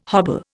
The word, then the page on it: hobble: Indian